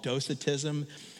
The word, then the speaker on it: Docetism: This was called Docetism. This is spelled D-O-C-E-T-I-S-M.